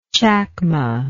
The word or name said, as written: chacma